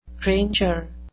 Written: granger

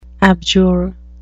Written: abjure

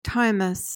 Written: timeous

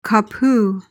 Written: kapu

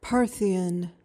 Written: parthian

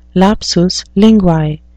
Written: lapsus linguae

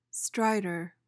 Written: stridor

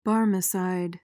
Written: barmecide

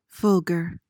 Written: fulgor

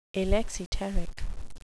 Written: alexiteric

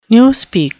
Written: newspeak

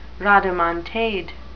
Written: rodomontade

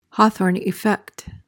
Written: Hawthorne effect

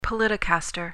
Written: politicaster

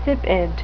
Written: sipid

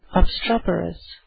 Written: obstreperous

